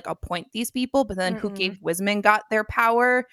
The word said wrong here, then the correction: Wizengamot: Is that how you say it? Or is it Wizengamot? – It's Wizengamot.